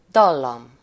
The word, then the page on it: dallam: /ˈdɒlːɒm/